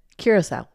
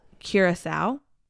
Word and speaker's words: Curaçao: Then they would go to Curaçao. – Okay. – Is that how you pronounce it? – Curaçao. – Curaçao.